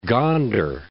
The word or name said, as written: Gondar